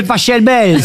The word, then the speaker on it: Pachelbel: Catelloni, c'est Pachelbel. – S'il est allemand, ça se dit Pachelbel. – Exactement, ouais. Ça pourrait se dire éventuellement Pachelbel, mais on dit en tout cas, ici en France, le canon de Pachelbel.